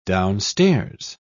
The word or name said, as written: downstairs